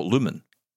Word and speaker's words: Lumen: I typed L-U-M-I-N because it's Lumin, but it's not. It's L-U-M-E-N. So if you pronounce it Lumen, then people know what to type to look for it. – But that's right. No, but then you type L-O-O because you think it's Lou, as in the Lou.